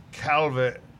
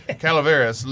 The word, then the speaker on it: Calaveras: I picked up a Crown Heads Las Calaveras. Calaveras. – Calaveras. – I got to put the syllables in the right order. Calaveras, limited edition 2021.